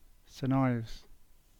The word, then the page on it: Saint Ives: English